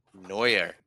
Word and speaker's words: Neuer: Manuel Neuer.